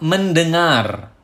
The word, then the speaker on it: mendengar: Number 12, mendengar. Mendengar, mendengar. Remember, we have nasal sound in bahasa mendeng, mendengar, and also you need to roll the R and pronounce it clearly.